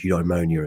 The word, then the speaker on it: eudaimonia: So a word that looms large in Aristotelian virtue ethics is the word eudaimonia, and that's spelled E-U-D-A-I-M-O-N-I-A, eudaimonia.